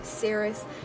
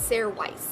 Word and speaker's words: Sairys: and it is spelled S-A-I-R-Y-S and is pronounced S-A-Y-R-I-S-S. So like Sarah-is, you know what I'm saying? Not Sarah Weiss, because that's kind of how it's like spelled.